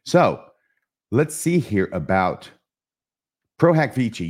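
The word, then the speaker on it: pro hac vice: And it says Pro Hac Vici forthcoming. At least that's how I learned to pronounce that in law school at the University of Texas at Austin. Pro Hac Vici.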